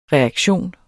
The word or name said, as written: reaktion